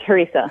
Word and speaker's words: harissa: and do you say harissa, or how do you pronounce it? – Yes, I think it's harissa.